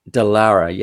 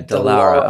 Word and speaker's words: Dallara: Their name's Dalla. You can pronounce Dallara. Yeah, Dallara.